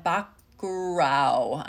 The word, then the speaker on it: Bacurau: They are having the virtual screening of Bacurau.